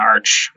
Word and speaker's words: ARCH: And those models are pronounced Garch and Arch, which kind of Garch doesn't really sound good, but you know, that's what it is. It stands for generalized Arch.